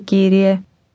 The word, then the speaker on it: Κύριε: Όχι, κύριε. Κύριε. Κύριε. Όχι, κύριε. – Did you hear the rolling R sound?